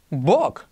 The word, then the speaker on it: book: Pero ahora bien, ¿cómo se pronuncia libro? Book. ¿Qué sentido tiene si se escribe igual?